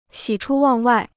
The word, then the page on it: 喜出望外: xǐ chū wàng wài